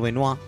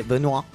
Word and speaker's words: Benoit: la Pedro Benoit, como bien pronunciaba. – Gracias, Benoit.